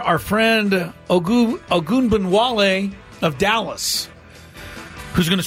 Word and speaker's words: Ogunbowale: But you have to pronounce her name in order Ogunbowale.